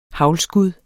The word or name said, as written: haglskud